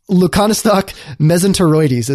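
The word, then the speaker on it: Leuconostoc mesenteroides: I would not say that I necessarily say these things correct, but Lacanostoc mesenteroides is how it's pronounce it.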